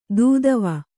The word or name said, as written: ದೀದಿವಿ